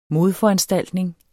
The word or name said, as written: modforanstaltning